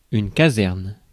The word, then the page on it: caserne: /ka.zɛʁn/; France